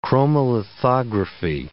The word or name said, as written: chromolithography